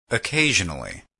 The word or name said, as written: occasionally